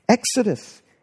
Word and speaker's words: Exodus: That's how you pronounce it in Greek, Exodus.